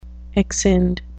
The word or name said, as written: exscind